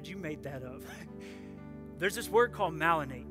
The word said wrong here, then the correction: myelinate: I think myelinate is how you say it.